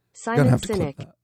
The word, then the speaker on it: Simon Sinek: Simon Sinek.